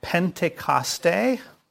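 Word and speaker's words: Pentecoste (Πεντηκοστή): The first one is Pentecoste, which is how you pronounce it in the Greek, but it is Pentecost, of course.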